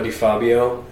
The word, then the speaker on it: Di Fabio: Uh, well, you can go on Spotify, and Luca Di Fabio is my name. Uh, D is with the is spelled D I, not D E.